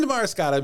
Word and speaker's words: Damariscotta: Damariscotta? – Damariscotta.